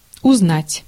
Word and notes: узнать: /ʊzˈnatʲ/

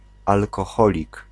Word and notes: alkoholik: [alkɔˈxɔlik]

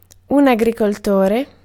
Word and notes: agriculture: France; [a.ɡʁi.kyl.tyʁ]

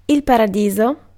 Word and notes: sky: /skaɪ/

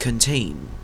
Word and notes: contain: /kənˈteɪn/; Canadian English

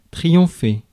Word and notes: triompher: France; [tʁijɔ̃fe]; French

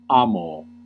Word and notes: aimer: /e.me/; /ɛ.me/

